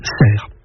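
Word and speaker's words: Sercq: Mais que dès cette époque, la consonne finale n'a plus été articulée et que l'on a dit ensuite serre.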